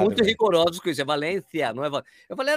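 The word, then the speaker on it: Valencia: Tá falando errado, é Valência, tem que ter outro. Valência.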